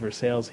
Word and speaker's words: Versailles: Now, it's spelled Versailles, but it's called Versailles here. And if you said North Versailles, no one here would know what you were talking about.